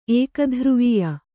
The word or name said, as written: ಏಕಧ್ರುವೀಯ